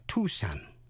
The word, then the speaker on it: Tucson: Thí dụ như trong bài trước, quý vị còn nhớ tên tiểu bang K-A-N-S-A-S đọc là Kansas. Nhưng tiểu bang A-R-K-A-N-S-A-S có âm S câm đọc là Arkansas. Tên thành phố Tucson, thủ phủ của tiểu bang Arizona, đọc là Tucson. Tucson, chứ không đọc là Tucson.